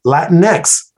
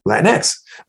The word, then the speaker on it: Latinx: And I says, well, you know, just think of the X, E-X. This word's, you know, right. – Mexico, M-E-X, Mexico, Mexico, right? Mexico, you know, Latinx.